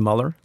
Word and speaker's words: Mueller: One small point before I put Ann on the hotspot, and that is it's pronounced Mueller. It's Bob Mueller.